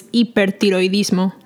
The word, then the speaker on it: hipertiroidismo: And then if they have hyperthyroidism, so you're just going to add an O at the end and the H, you never pronounce it silent. So it's hipertiroidismo.